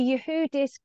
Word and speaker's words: Yehudis: And we've got the lovely Yehudis Goldsobel here today. And I think I've just said her name wrong after just practicing it. Never mind. – You got the key bit right. My name is Yehudis.